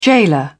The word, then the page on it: gaoler: British English